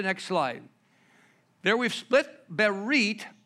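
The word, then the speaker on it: berit (ברית): It's pronounced berit.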